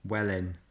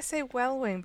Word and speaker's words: Welwyn: So I thought I'd just let you know it's pronounced Wellin. – I think I say Wellwing because in Welsh you pronounce W-Y-N as win.